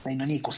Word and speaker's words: Deinonychus: Deinonychus sería la pronunciación correcta, pero tengo la misma pronunciación que cuando tenía 5 años y lo leía. – y, además, para Velociraptor queda mucho mejor que Deinonychus.